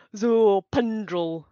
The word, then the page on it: Zopandrel: Phyrexian